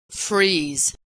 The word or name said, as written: freeze